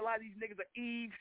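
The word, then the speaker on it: Yves: Notice they get a nigga a name that sounds like a woman's name, even though they spell it Y-B-E-S, like Eve Saint Laurent.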